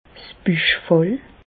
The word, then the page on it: 's Büchfall: Bas-Rhin; Reichshoffen